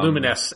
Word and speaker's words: Lumines: Yeah, Lumines, as it is actually pronounced.